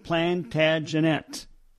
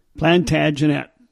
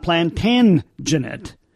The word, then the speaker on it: Plantagenet: Well, if you want to sound like a local, you pronounce it Plantagenet. Plantagenet. Not Plantagenet. Plantagenet. That's how you pronounce it if you want to sound like a local.